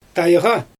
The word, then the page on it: tajɣa: Xyzyl